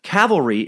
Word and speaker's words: cavalry: Like many people, you pronounce the word cavalry incorrectly. Cavalry is the term used to describe horse-backed infantry in which you were referring to. Calvary is where Jesus was reportedly crucified. Please, for the love of all the things that suck, pronounce it correctly.